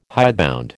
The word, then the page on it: hidebound: American English